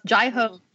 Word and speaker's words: Jai Ho: Pronounced Jai Ho.